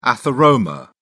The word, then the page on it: atheroma: British English